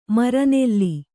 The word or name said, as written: ಮರನೆಲ್ಲಿ